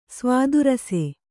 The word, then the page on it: ಸ್ವಾದುರಸೆ: svādu rase; Kannada